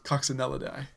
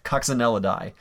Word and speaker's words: Coccinellidae: Ladybugs are now Lady Coxophilidae. Did I say that right? – No. Coccinellidae. – Coccinellidae. Lady Coccinellidae.